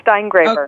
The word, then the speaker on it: Steingraber: Yeah, Steingraber.